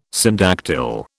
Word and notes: syndactyl: British English

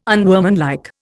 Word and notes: unworkmanlike: Indian

